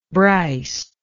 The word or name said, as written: Bryce